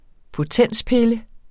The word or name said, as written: potenspille